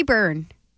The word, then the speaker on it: Bourne: Jordy Bourne. I believe it's Bourne, like the town on Cape Cod. – Okay. B-O-U-R-N-E. Jordy Bourne. Is that how it's spelled? B-O-U-R-N-E? – Yep.